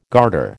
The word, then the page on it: garter: American English